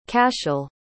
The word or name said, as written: cashel